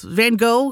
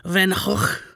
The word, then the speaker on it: Van Gogh: Van Gogh, he's from the Netherlands. He's Dutch, so the right way to say his name is probably something like Van Gogh. But Americans tend to say Van Gogh, so I'm going to go with Van Gogh.